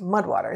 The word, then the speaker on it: MUD/WTR: That's MUD/WTR, pronounced Mudwater.